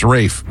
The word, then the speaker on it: Ralph: Pronounced Rafe, even though it's spelled Ralph.